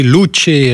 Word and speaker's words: Luce: La Luce, prononcée Luce, c'est son nom en italien, la lumière,